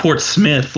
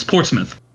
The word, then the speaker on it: Portsmouth: It's not Port Smith like S M I T H. It's Portsmouth.